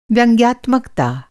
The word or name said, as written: व्यंग्यात्मकता